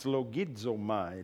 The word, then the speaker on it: logizomai: It's L-O-G-I-Z-O-M-A-I, logizomai. And when you see M-A-I on the end of any Greek word, it's my, but it's ma-e is actually how it's pronounced. You pronounce every letter of the Greek language. Logizomai, and you even add some that aren't even in there. With Z, you put a D in front of it, generally speaking, logizomai, okay?